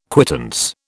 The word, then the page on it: quittance: British English